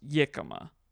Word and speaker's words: jicama: It's either pronounced jicama or yicama. – I think I've heard of jicama before. – I don't think I've ever heard of yicama. But then our friend Thomas, who speaks Spanish, said that it's pronounced yicama.